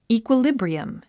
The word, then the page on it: equilibrium: ìːkwəlíbriəm; イークヮリブリアム